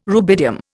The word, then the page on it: rubidium: Indian